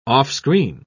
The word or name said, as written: offscreen